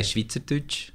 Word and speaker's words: Schweizerdeutsch: Is it Switzerdeutsch? Is that how it's pronounced? How is it pronounced? – Well, it depends. Every dialect, Swiss German has a lot of dialects, and everyone will say differently. I say Schweizerdeutsch.